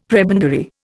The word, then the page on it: prebendary: Indian